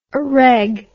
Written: areg

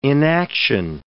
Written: inaction

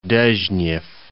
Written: Dezhnev, Cape

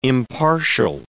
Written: impartial

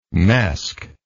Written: mesc

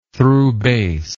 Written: throughbass

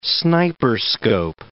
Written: sniperscope

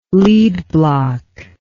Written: lead block

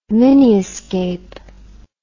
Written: miniascape

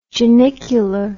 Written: genicular

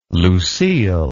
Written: Lucille

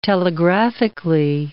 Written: telegraphically

